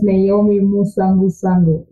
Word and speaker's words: Nahomie Musangusangu: My name is Nahomie Musangusangu.